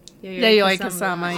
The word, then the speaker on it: Yayoi Kusama: Am I saying that correctly? – Yayoi Kusama, yeah.